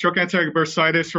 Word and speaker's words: trochanteric bursitis: So today we're going to be talking about stem cell regenerative medicine and a condition called, is it trochanteric bursitis? Am I saying it right? – Yeah, it's trochanteric bursitis or greater trochanteric bursitis, yes.